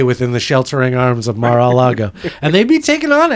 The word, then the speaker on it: Mar-a-Lago: Am I saying it right? Mar-a-Lago. Yeah.